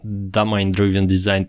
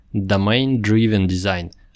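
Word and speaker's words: Domain-Driven Design: Во-первых, в предыдущей записи я использовал некорректное произношение. Раньше никогда об этом не задумывался, но правильно говорить так. Domain Driven Design, а не Domain Driven Design.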